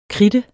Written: kritte

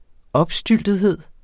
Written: opstyltethed

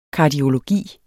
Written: kardiologi

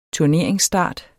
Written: turneringsstart

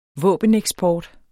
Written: våbeneksport

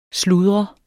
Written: sludre

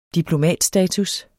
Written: diplomatstatus

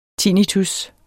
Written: tinnitus

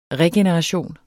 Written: REGENERATION

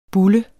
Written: bulle

